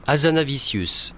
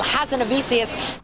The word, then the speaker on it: Hazanavicius: Michel Hazanavicius. – It's one way of pronouncing his name, or. – Michel Hazanavicius.